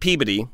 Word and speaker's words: Peabody: It's pronounced Peabody. – Peabody. Peabody. – And when okay. if you're from Massachusetts, we say Peabody.